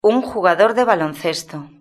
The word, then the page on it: un jugador de baloncesto: European Spanish